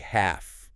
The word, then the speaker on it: half: The L is silent. You don't say it. – Exactly. It sounds like half. H-A-F. Half.